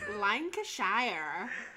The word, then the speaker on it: Lancashire: Why am I struggling to say Lancashire? – Yeah. – I'm trying to say it like an American. Lancashire. – Lancashire.